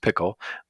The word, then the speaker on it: Pkl: And it's spelled PKL, I should point out, which should be in the title, but pronounced Pickle, but PKL.